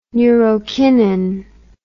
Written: neurokinin

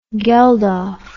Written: geldof

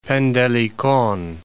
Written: Pendelikon

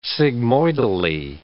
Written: sigmoidally

